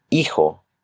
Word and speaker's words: hijo: Hijo. With the letter H. The letter H is silent. Just go to the next letter.